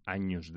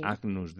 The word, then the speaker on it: Agnus: Entonces, esa G y esa N se pronuncian como una ñ. También está Agnus Dei, ¿no? Sí. Agnus Dei es Agnus Dei.